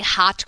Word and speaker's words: haute: I mean, listen, the woman who owns a hot cookie in Atlanta is a genius when it comes to cookies. – It's called a hot cookie? – Spelt H-A-U-T-E, yes. – Oh, like hot? – Is that French?